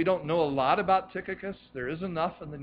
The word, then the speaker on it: Tychicus: Because the hillbilly in me wants to say Tychicus. And that's not how you say it. It's Tychicus.